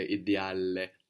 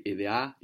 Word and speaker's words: ideale: But careful not to pronounce two L's on the end there, ideale. No, we want to say ideale. Non è ideale.